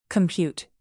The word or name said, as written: compute